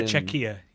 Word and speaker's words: Czechia: I think they pronounce Czechia. it, but I it was on Eurovision. Either, but... Yeah, yeah, they Eurovision. prefer Czechia.